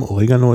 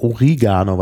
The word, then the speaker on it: Oregano: Oregano ist ja auch ein schönes Gewürz, was Deutsche meistens falsch aussprechen. – Wir sagen im Deutschen immer Oregano. Oder Oregano. – Oregano. – Viele. Oder Origano, weil es auf Lateinisch tatsächlich Oregano heißt. Das ist eigentlich ein griechisches Wort.